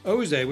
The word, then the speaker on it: OZÉ: So, Oze, which rhymes with Ole, is a Ghana-based fintech designed to improve access to capital for African small businesses.